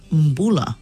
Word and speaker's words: Mbula: So if you see the letter B, it's pronounced like a MB. So when you, something like the word number, yeah, so MB. So that means when we say hello in Fiji, it's Mbula.